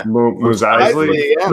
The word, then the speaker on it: Mos Eisley: Mos Eisley.